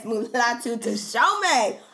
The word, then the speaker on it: Mulatu Teshome: Mulatu Teshome. – Yes, Mulatu Teshome.